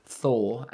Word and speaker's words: Thor: But on your name, when you say Tor, it's Tor. – Tor.